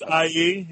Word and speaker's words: Aya: Is that what I'm pronounced IE? Yes, because Hal Jordan hits on anything with a woman's voice.